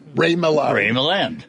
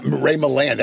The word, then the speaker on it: Milland: Ray Milland. Yeah, Ray Milland.